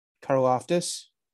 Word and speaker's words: Karlaftis: All right, the Texans' second pick, I have them taking George Karlaftis. I totally said that right. Was I close? – Karlaftis. – Yeah. Is that how you say it? I don't know. – Correct.